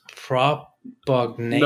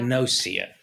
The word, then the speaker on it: prosopagnosia: Uh, I think it's propagnasia.